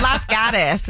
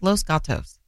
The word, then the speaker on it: Los Gatos: Las Gatos is how we pronounced it. Las Gatos. It's Los Gatos.